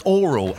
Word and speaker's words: aural: Aural. – Oh, yeah, it's pronounced oral. A-U-R-A-L is pronounced oral. That's actually a fact.